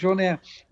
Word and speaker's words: Jean: No, možná to zní překvapivě, ale u osobnosti, jakou je Wyclef Jean, on se vyslovuje Jean, protože je z Haiti, děkuji za upřesnění.